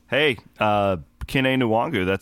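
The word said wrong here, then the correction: Nwangwu: Wongwu, I guess. We mispronounced his Wongwu.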